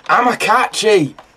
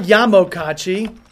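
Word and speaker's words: Amokachi: Amakachi! – That's what it was, not Yamokachi. Finally. – What's it? How do you spell it? – A-M-O-K-A-C-H-I. I added a Y.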